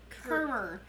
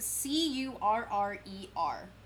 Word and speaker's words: Currer: She also, when she did publish Jane Eyre, she used the male pseudonym Currer Bell. Currer. It's spelled C U R R E R. Currer.